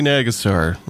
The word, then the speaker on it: Nagassar: Rohadi Nagassar. Yep. – That's right. In your book, you say it is pronounced exactly like it's spelled.